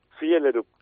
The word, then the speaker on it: Fjellerup: Fjellerup. – Fjellerup, ¿eh? – Claro. – Perfecto. – La segunda letra es una I, la sí. – Doble L es una L sola.